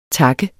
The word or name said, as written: tak